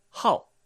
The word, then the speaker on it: 号: 5 は、ハオと発音します。